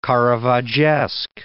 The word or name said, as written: Caravaggesque